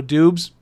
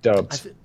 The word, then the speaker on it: Doubs: It's pronounced Dubs, right? Romeo Dubs? – Dubs. – Dubs. – Yes. – There's an O in there that is just not belong in that last name.